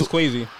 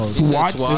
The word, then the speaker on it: Kwazi: His name is Kwazi. – You said Swatsky. – His name is Kwazi. – It's spelled K-W-A-Z-I.